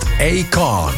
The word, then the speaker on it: Akon: Dat deed ze samen met Akon. Ja, zo moet je dat uitspreken.